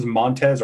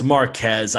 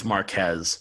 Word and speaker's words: Marquez: Did we ever find out if it was Montez or Marquez? – It's Marquez. I always get it wrong. It's Marquez.